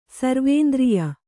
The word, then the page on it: ಸರ್ವೇಂದ್ರಿಯ: Kannada